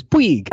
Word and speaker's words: Puig: Well, because if you look at a pronunciation guide for Puig, it's P-W-E-E-G, which is Puig.